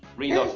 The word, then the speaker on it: Redux: Redux, no sé cómo se, pronuncia. R-E-D-U-X. Redux.